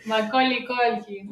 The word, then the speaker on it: Macaulay Culkin: pero es un grado de fama que ponele Macaulay Culkin, no sé, siempre nunca lo pronuncias bien. – Macaulay Culkin.